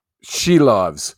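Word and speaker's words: Šilovs: Silovs or Shelovs? – Shelovs.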